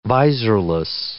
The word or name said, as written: visorless